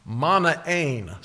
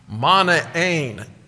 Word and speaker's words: Manaen: I didn't know, so I went to my Greek New Testament, and I'm pronouncing it the way it is written in Greek. Manaen. Say that with me, please. Manaen. Emphasis on the last syllable. Manaen.